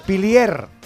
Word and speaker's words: pilier: no pero de Pirlier, ¿no? ¿Se dice así? Parlier, Perlier. – El que patea. No lo sé. ¿Cómo? – Pilier. – Bueno, el capitán... – De Pilier.